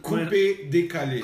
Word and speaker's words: Coupé Décalé: How do you say that again? Because I'm Coupé Décalé. Coupé Décalé. Coupé Décalé. – Décalé. Décalé. – Yeah. Coupé Décalé. Yes.